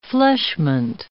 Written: fleshment

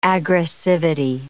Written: aggressivity

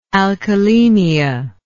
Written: alkalemia

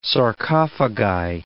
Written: sarcophagi

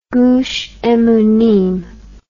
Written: gush emunim

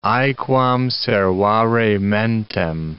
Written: aequam servare mentem

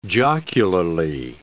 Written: jocularly

